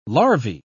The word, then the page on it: larvae: British English